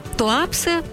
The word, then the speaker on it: Туапсе: Туапсе, ударение в адыгейском языке падает на второй слог, и Паук.